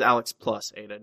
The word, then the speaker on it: Alex + Ada: I mean, when I you search for it, it says Alex plus Ada, not Alex and.